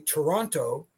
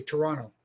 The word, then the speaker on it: Toronto: But up here in my hometown, which is pronounced properly Toronto, if you're from here, you just say Toronto. It's almost like you forget the second T.